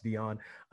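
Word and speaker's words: Deeon: So I'm Dion Brown.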